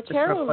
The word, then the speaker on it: Akeru: I know that you have another process called Akeru. I don't know if I'm saying it right. A-K-E-R-U. – Akeru? Yes, Akeru, yes.